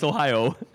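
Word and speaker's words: Ohia: I'm not mispronouncing it. That's the way it is. Because I know Denise is looking at me like, you're saying that wrong. It's Ohio.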